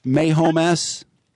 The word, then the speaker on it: Mahomes: Patrick Mahomes. – How do you spell it, sir? – M-A-H-O-M-E-S.